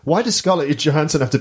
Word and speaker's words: Johansson: Scarlett Johansson is actually how you pronounce it.